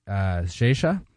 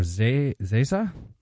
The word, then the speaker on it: Xexa: We've got Kemes Tu by Zesha or Zesa. X-E-X-A, but of Portuguese pronunciation and origin.